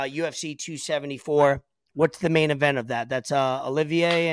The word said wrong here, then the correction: Oliveira: and Oliveira. – Oliveira, that's how you say it, sir.